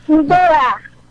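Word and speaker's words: Mbyá: Imbiá. – Imbiá? Imbiá. – Na verdade, esse MB com Y forma uma sonoridade, um problema que não existe na língua portuguesa, né? Eu diria aproximadamente Imbiá.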